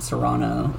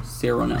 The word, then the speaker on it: Cyrano: I keep calling it Serrano, like the pepper. Cyrano.